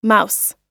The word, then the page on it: mouse: noun